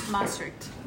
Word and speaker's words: Maastricht: Maastricht.